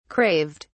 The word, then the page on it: craved: British English